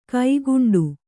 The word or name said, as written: ಕೈಗುಂಟೆ